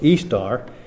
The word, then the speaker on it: Ishtar: And there's a fairly lengthy article on Ishtar, spelled either with the H or without the H, and the I pronounced as an E. So Ishtar is the name of the chief goddess of the Babylonian and Assyrian empires, the counterpart of the Phoenician Astarte.